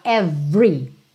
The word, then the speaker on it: every: Então lembra que por padrão, esse é do meio do every não é pronunciado. Você fala every, every.